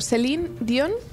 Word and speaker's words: Celine Dion: Celine Dion, ¿lo he dicho bien? – Sí.